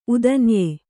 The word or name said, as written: ಉದನ್ಯೆ